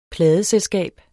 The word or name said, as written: pladeselskab